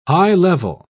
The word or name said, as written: high-level